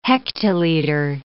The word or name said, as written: hectoliter